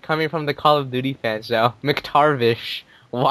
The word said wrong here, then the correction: McTavish: Isn't it McTavish? – McTavish? – There's no R in there.